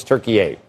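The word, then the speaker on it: Türkiye: Because in 2022, Erdogan change the name internationally, and there's an umlaut over the U because it sounds more like, if you're Turkish, that's how you pronounce it, and it just hasn't been adopted by it necessarily widely in the United States because we don't have umlauts if you're writing something, right? But it's Turkey A.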